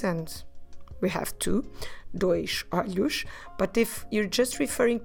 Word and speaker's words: olhos: First, the word for eyes is olhos.